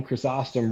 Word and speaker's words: Chrysostom: A good friend of mine always would say John Chrysostom rhymes with awesome.